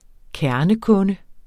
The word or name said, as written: kernekunde